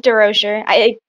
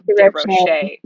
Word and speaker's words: DeRocher: It's DeRocher. People mispronounce it all DeRocher. The time. Yeah. – I'm sure they try to make it like fancier. – Yeah. DeRocher. Yeah. Yeah. Like it's like the Americanized French.